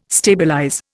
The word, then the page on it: stabilize: Indian